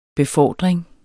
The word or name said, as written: befordring